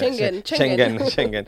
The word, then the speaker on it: Schengen: Es decir, si Italia ha tenido un mal gesto hacia España, anulando el espacio Schengen, o cómo se pronuncia, Schengen.